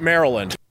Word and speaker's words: Maryland: Like Meryl Streep, Maryland.